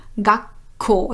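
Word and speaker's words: Gakko (学校): Und weil bei Gakko eigentlich Gakuko heißen würde, aber hier zwei harte Laute, zwei stummlose Laute aufeinandertreffen, wird aus dem Gaku, aus dem Q ein kleines Zu und das verstärkt das nächste K vom Ko. Gakko, also wie ein Doppel-K.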